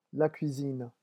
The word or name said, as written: la cuisine